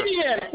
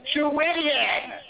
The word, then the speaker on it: Chuidian: Chuidian, for the love of God, it is not difficult to pronounce my name, Chuidian.